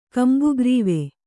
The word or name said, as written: ಕಂಬುಗ್ರೀವ